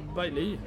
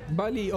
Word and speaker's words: Bailly: Jag vet inte hur man ska uttala det på rätta sagt. Hur skulle du säga? – Jag skulle säga Bailly. – Bailly, okej, okay, vi det håller oss till det.